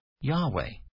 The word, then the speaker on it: Yahweh: Recent archaeological discoveries have indicated that this term should be pronounced Yahweh.